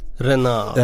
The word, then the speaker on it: Renard: Renault som han heter, tror jag uttalar rätt. Hervé Renard. Renard ska man nog säga.